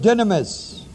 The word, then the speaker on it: dunamis: The word eternal is the Greek word Aidios, A-I-D-I-O-S. The word power is the word dunamis, D-U-N-A-M-I-S. Godhead is the Greek word theotes, spelled T-H-E-I-O-T, long E, S, theotes, His eternal, dunamis, and Godhead.